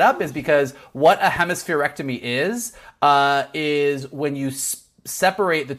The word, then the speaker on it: hemispherectomy: Kind of to your point at the beginning, what the way you pronounce it is hemispherectomy.